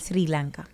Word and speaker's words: Sri Lanka: Sri Lanka.